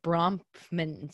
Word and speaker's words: Bronfman: So the the other um people that I wanted to touch on too that become very important are the Bronfman sisters, I guess is how you pronounce it. – Um, yes.